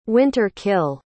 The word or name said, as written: winter-kill